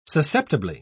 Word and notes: susceptibly: British English

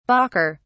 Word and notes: balker: British English

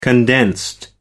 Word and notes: condensed: British English

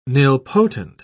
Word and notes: nilpotent: British English

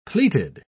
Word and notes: pleated: British English

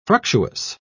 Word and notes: fructuous: British English

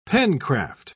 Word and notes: pencraft: British English

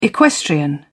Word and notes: equestrian: British English

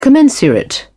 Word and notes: commensurate: British English

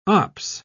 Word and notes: ops: British English

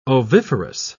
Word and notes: oviferous: British English

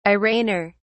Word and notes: arraigner: British English

